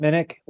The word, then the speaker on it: Minnich: I can be found on Twitter at Chip Minnick. Last name is spelled M-I-N-N-I-C-H.